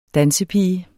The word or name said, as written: dansepige